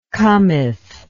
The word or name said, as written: cometh